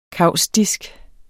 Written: kaustisk

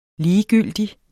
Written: ligegyldig

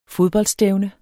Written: fodboldstævne

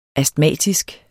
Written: astmatisk

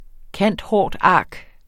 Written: cand.hort.arch.

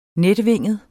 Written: netvinget

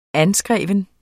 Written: anskreven

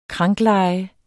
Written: krankleje

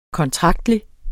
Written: kontraktlig

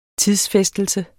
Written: tidsfæstelse

